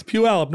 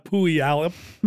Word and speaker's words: Puyallup: It's Puyallup, not Puyallup.